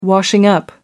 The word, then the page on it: washing-up: American English